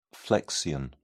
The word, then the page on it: flexion: British English